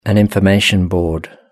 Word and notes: an information board: British English

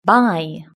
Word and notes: buy: British English

